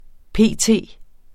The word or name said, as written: p.t.